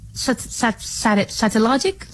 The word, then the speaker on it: Satellogic: Satellogic, se dice así, Satellogic Merlin Satellite.